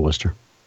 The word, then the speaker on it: Worcester: I grew up in South Worcester, Mass.